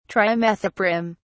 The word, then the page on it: trimethoprim: British English